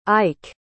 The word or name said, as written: ike